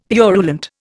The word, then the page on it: purulent: Indian